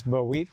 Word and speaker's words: Bowit: We had the name of this ministry has always been hard for me to pronounce, but it's Bowit.